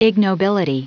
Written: ignobility

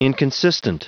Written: inconsistent

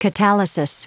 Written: catalysis

